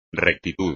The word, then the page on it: rectitud: espagnol